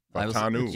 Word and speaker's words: Fautanu: Fautanu. – Yes, Fautanu. I heard Missy Matthews the other day talking about it. It's a U, but it's pronounced as an O.